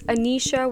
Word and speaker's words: Aneesha: It's Aneesha with two E's, so you smile when you say my name, and Mad Hawk, like a mad bird, but just H-O-K.